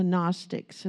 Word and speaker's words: Gnostics: These are things that come from the Gnostics, and it starts GN. There's the G is silent, but Gnostics.